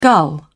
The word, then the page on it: gull: British English